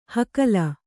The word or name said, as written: ಹಕಲ